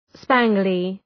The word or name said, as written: spangly